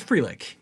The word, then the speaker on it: Frelick: Sal Frelick, am I saying his name right? – It's Frelick, yes.